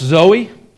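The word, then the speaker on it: Zoe (ζωή): But in the text here, the wording that we are dealing with here, the term that is used for life is pronounced Zoe. That's sort of how it's pronounced.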